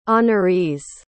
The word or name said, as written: honorees